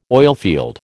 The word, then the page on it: oilfield: American English